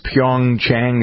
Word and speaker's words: Pyeongchang: How do we pronounce it? It's Pyeongchang. Is that how you pronounce the Pyeongchang. – Uh-huh. Say it again. – Sounds right. It's what you said, Pyeongchang.